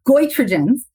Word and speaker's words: goitrogens: Last one on your list, and maybe I'm going to mispronounce this one as well, but goitrogens. Or goitrogens, which goitrogens. Is it? I've heard it both ways. – Goitrogens. And that's because of its link to goiter.